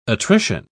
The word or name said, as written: attrition